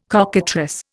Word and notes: cockatrice: Indian

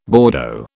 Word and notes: Bordeaux: British English

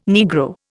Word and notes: Negro: Indian

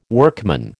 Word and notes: workman: American English

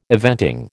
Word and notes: eventing: American English

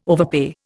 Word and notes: overpay: Indian

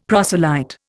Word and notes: proselyte: Indian

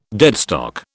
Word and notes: deadstock: British English

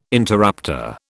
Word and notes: interrupter: British English

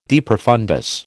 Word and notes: de profundis: American English

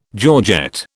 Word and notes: georgette: British English

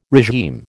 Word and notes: regime: American English

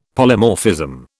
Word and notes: Polymorphism: British English

